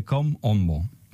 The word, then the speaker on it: Kom Ombo: Y es el templo de Kom Ombo.